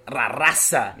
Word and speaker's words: La Raza: See how I say it? – Mm-hmm. – Say it, La Raza. – Yeah. – Say it like me. Mm. La Raza. – La Raza. – No, La Raza. – La Raza. – It's almost like Ra Raza.